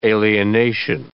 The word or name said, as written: alienation